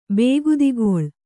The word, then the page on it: ಬೇಗುದಿಗೊಳ್: Kannada